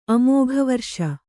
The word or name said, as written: ಅಮೋಘವರಿಷ